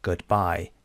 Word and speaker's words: goodbye: چند بخشه، اینجا ببینید goodbye دو بخشه، multisyllable. خب syllable اولش اینجاست، first syllable and second syllable. استرسش کجاست؟ میگیم goodbye. Goodbye استرس قسمت دومه.